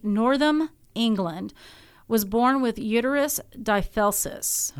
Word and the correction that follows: didelphys: that's and I just completely butchered it. It's didelphys. – Didelphys. It. Yeah. D i d e l p h y s.